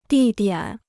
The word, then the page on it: 地点: dì diǎn